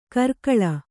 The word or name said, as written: ಕರ್ಕಳ